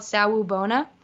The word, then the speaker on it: Sawubona: So this is called Sawubona. It's spelled S-A-W-U-B as in boy, O-N-A.